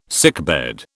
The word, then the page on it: sickbed: British English